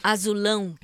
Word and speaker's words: Azulão: Azulau, Azulau, Azulau. Zeg ik het goed? – Azulão.